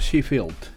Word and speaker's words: Sheffield: Así señor, Sheffield.